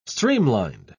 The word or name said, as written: streamlined